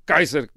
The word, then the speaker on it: Kaiser: Kaiser é a maneira, aliás, como se pronuncia César em latim, Kaiser, é assim que se pronunciava em latim o nome de César,